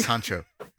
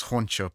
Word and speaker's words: hancho: Yes, Hancho. It's spelled, I thought it was like, because I looked it up and I thought it was Honcho, but it's actually very similar. It's Hancho.